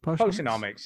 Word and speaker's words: Potionomics: Potionomics, yeah, sounds about right.